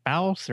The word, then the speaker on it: Baus: Is that Baus or Boss or? – Yep, rhymes with house.